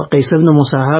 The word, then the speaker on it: قیس ابن مسهر: حالا قیس ابن مسهر رو آیا، البته میخوام بگم که تلفظ صحیح همینه و عنوان سفیر هم که ایشون پرسیدن.